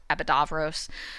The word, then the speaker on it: Epidavros: I tend to pronounce it with a V sound, Epidavros, but it's usually spelled with a U where that V sound is.